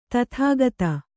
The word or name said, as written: ಧಾತುಗತ